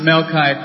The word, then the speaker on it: Melchi: But seriously, she just pronounced Melchi correctly, not once, but twice.